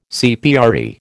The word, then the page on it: CPRE: American English